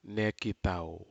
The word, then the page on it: nekitau: Aneityum